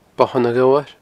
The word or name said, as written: Bothan a' Ghobhair